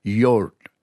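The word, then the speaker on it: yoğurt: And then you pronounce R and T, but instead of saying yogurt t, with a T at the end, you don't release the T, you hang on to it. So, yurt.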